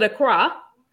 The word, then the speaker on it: Accra: That's how you pronounce it, Accra.